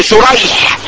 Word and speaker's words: شريح: طيب ومنها ان يتلف اسم الراويين خطا لا لفظا ويتفق اسم ابيهما لفظا وخطا زي ايه شريح ابن النعمان وسريج ابن النعمان. ابوهم زي بعض ده النعمان وده ايه لكن الاول الراوي اسمه ايه الاولاني بالشين شريح بالشين والحاء المهمله والاخر بالسين والجيم الايه جمع سريج.